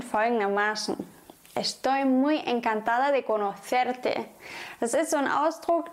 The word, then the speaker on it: conocerte: Estoy muy encantada de conocerte.